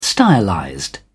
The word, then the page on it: stylized: British English